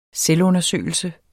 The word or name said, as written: selvundersøgelse